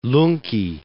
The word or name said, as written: Lungki